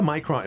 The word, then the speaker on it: Micron: Well, it's interesting because, you know, you got the Micron.